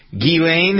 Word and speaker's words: Ghislaine: Ghislaine, have I finally figured out how to pronounce her name correctly? – Correct.